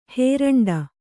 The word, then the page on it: ಹೇರಂಡ: hēraṇḍa